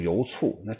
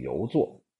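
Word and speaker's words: 游酢: 你如果读成油醋，那这个人将来可能只能卖个醋啊，老陈醋，所以应该读成游作。那么这个游作呀，曾经有一个成语叫城门立雪。哎呀，我这么一说，大家就熟悉了。杨时和游作第一次登门拜见程颐先生的时候，不巧先生在书中啊坐着睡着了。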